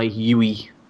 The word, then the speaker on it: Yui: I'm going to go with the Full Metal Alchemist Brotherhood intro, again by Yui, I think it's pronounced.